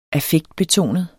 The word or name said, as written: affektbetonet